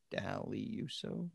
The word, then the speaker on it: Daliuso: D-A-L-I-U-S-O, something like that. Daliuso? Dalyuso.